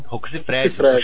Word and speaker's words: Rocco Siffredi: Como é que fala o nome dele? – Rocco Siffredi. Chama de Rocco Siffredi.